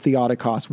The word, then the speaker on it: Theotokos: But what I will say is that when it comes to salvation, they are calling Mary Theotokos or Theotokos, but I think it's better pronounced Theotokos, which means the God-bearer, okay, the mother of God.